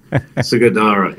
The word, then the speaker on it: Sugadaira: Sugadaira in Japan. Is that how do you pronounce it? Is that right? – Not bad, mate. Sugadaira, yeah. – Sugadaira, okay, cool.